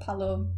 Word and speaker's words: Pallot: A G, then a B, and then Pallot P A double L O T but I think oh. I'm going to pronounce it Palo. Yeah, yeah, Palo.